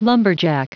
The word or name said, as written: lumberjack